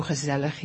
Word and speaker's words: gezellig: How do you say that? – Gezellig. – Gezella? – It's almost like flippin' floppin'.